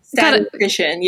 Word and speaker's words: statistician: Is it statistician by day? – Yeah, how do you say that? – Statistician, yeah.